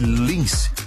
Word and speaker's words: LNNCE: Escreve-se dessa maneira, mas pronuncia-se Lins.